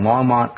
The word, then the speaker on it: Longmont: So if you're in the Colorado area, hope to see you at the Longmont Journey Church, 2000 Pike Road, Unit A in Longmont, Colorado. Did I say that right, Bob? – Yes.